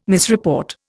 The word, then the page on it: misreport: Indian